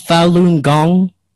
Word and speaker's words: Falun Gong: It's pronounced Falun Gong? – Correct.